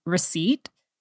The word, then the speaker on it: receipt: One of the words that I grew up with and mispronounced all the way until college was receipt. And it was the same thing with knife. The P is silent in receipt.